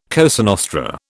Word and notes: Cosa Nostra: British English

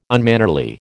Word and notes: unmannerly: American English

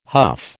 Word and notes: huff: American English

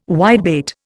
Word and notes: whitebait: Indian

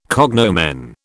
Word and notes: cognomen: British English

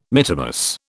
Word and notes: mittimus: British English

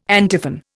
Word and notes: antiphon: Indian

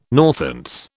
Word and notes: Northants: British English

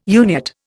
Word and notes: Uniat: Indian

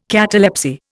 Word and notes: catalepsy: Indian

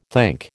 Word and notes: think: American English

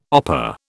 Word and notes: opah: British English

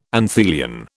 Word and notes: anthelion: British English

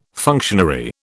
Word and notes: functionary: British English

